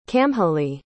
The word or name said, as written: camhlaidh